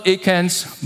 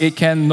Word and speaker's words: Achan: Over Achan's body. Now it's pronounced Achan, not Achan. In case you are thinking he's Asian or Cantonese.